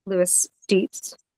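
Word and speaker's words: Loeys-Dietz: So the heavy hitters, the known connective tissue disorders that are associated with this risk, include Marfan syndrome, Lewis Dietz syndrome, and that's spelled Loeys hyphen Dietz, named after the Belgian doctor who defined it,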